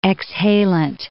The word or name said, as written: exhalant